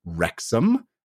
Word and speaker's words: Wrexham: And I am joined, as always, by my friend slash co-host slash nemesis, the guy who knows it's pronounced Wrexham and still didn't help me out at all, Mr. Christian Spicer.